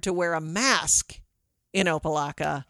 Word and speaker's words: Opa-locka: It is Opa-locka.